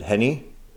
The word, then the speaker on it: Renni: And her name is spelled, R-E-N-N-I, but she pronounces it Henny, like Hennessy. – R-E-I? – R-E-N-N-I, but she pronounces it Henny.